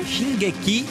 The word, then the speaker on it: Shingeki: Shingoki, como é que é? – Shingeki no Kyojin.